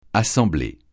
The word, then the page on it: assembler: francais